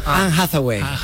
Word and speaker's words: Anne Hathaway: Anne Hathaway. – Eva, ¿está bien dicho? Está perfectamente dicho.